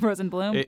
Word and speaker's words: Rosenblum: And sounds differently. Rosenbaum, Rosenblum.